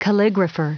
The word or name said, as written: calligrapher